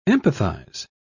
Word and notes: empathise: British English

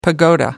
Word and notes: pagoda: American English

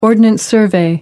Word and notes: ordnance survey: American English

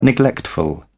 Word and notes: neglectful: British English

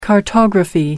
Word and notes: cartography: American English

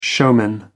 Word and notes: showman: American English

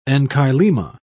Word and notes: enchylema: British English

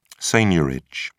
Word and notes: seigniorage: British English

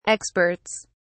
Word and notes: experts: British English

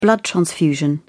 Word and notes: blood transfusion: British English

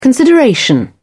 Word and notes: consideration: British English

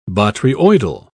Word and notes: botryoidal: British English

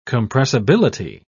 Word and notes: compressibility: British English

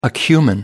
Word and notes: acumen: American English

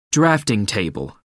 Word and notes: drafting table: American English

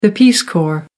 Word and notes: peace corps: American English

